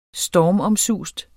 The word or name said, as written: stormomsust